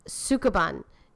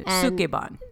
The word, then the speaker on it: Sukeban: Sukeban is S-U-K-E-B-A-N. – E-B-A-N. Sukeban.